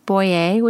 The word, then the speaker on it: Boyer: Boyer, which is my last name, and that's spelled B-O-Y-E-R on Instagram.